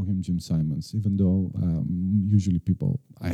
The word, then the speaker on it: Simons: Ah, I pronounce it Simmons. I still don't know. – I did my research. Some people call him Simmons. Some people call him Simons.